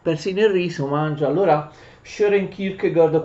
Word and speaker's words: Søren Kierkegaard: mi dicono quelli che se ne intendono che quelle due A si leggono come una specie di O e insomma più o meno in danese il nome del nostro filosofo si pronuncia Søren Kierkegaard.